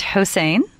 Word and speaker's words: Husen: It's spelled the German way, but it's pronounced Husen, like Barack.